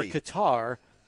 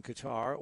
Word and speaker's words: Qatar: Or Qatar, depending on how you choose to pronounce it. Both are acceptable,